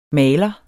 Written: maler

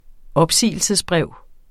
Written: opsigelsesbrev

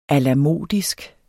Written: alamodisk

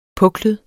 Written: puklet

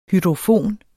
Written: hydrofon

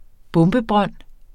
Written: bombebrønd

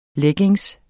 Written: leggings

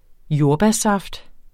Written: jordbærsaft

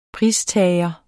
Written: pristager

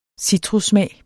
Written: citrussmag

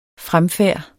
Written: fremfærd

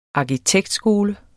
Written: arkitektskole

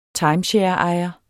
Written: timeshareejer

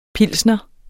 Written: pilsner

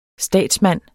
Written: statsmand

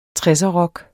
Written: tresserrock

